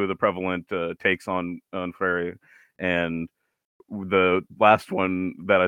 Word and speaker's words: Freire: Freire, no, Freire is correct. You said it right the first time.